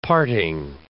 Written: parting

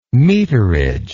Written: meterage

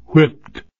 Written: whipped

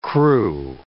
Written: crew

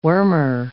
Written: wormer

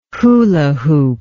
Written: hula hoop

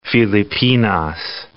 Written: Filipinas

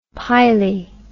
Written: pily

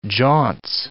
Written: jaunce

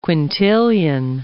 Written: quintillion

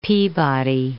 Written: Peabody

